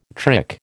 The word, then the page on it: trick: American English